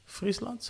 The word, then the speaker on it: Friesland: Straight uit Friesland, zeg ik het goed? – Ja, dat klopt.